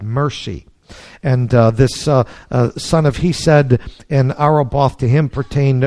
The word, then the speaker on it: Chesed: Verse 10, the son of Hesed, or Ben Hesed. Hesed, I'm not good at pronouncing it. It's Chesed would be mercy.